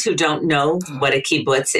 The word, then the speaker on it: kibbutz: So, Judy, first I'm going to say, I can understand why you're pronouncing it that way. And I love it. The creativity kibbutz, but it's actually the creativity kibbutz.